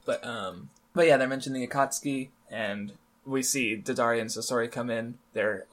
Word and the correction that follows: Deidara: It's actually pronounced Deidara, I think.